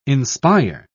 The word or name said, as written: inspire